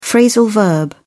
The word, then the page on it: phrasal verb: British English